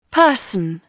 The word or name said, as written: person